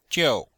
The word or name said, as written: jiu4